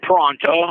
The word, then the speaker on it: Parenteau: Richard Pronto, my last name is spelled P-A-R-E-N-T-E-A-U,